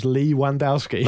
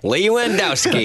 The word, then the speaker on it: Lewandowski: Phil Pierce also says, my highlight was a friend of mine actually pronouncing Lewandowski's name as Lee Wandowski. – Nice. – Lewandowski.